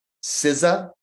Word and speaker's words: SZA: My number three album of the year is she spells it SZA, and apparently she pronounces it SZA.